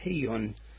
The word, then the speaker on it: Chiun: C-H-I-U-N. Chun. It's pronounced Hiyun in Hebrew.